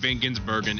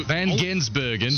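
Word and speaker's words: van Gisbergen: So solid start for Shane Van Gensbergen in Daytona. – Why won't you say it right? – So Van Gensbergen.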